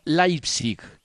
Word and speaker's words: Leipzig: Nos recuerda que el diptongo EI se pronuncia AI, o sea que no es Leipzig, sino Leipzig. Y no es Eintracht Frankfurt, sino Eintracht Frankfurt.